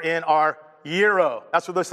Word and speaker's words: gyro: It's not gyro. I ordered those things, and I've said it wrong so many times. I had to play that back online like 14 times. Gyro. Gyro. That's what they're called.